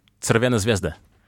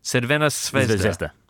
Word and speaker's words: Crvena Zvezda: Crvena Zvezda. – Crvena Zvezda.